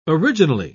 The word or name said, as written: originally